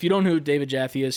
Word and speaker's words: Jaffe: I think it's Jaffe or Jaffe. So that you pronounce the E. David Jaffe, he tweeted out and he said, here's useless tweet, but if I don't say something, I will explode.